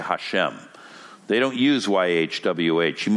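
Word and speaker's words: Hashem (השם): Instead, they substitute something for it in Hebrew that is pronounced Hashem, and it means literally the name.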